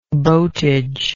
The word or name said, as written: boatage